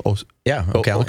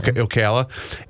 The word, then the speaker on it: Ocala: And I got to, is it pronounced Ocala? – Ocala. – Ocala.